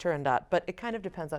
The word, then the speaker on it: Turandot: Well, it sort of depends on who you ask. So our maestro is insisting upon us singing Turandot. However, a lot of people also say Turandot. I personally actually am of the school that it should be pronounced Turandot, as it is based on a Persian fairy tale.